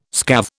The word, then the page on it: scathe: British English